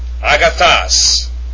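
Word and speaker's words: Agathos (ἀγαθός): The word of God is, and the Greek form for good is Agathos, Agathos, written Alpha, Gamma, Alpha, Theta, Omicron, Sigma. Smooth breathing mark over the first Alpha. Acute accent over the final Omicron, pronounced Agathos.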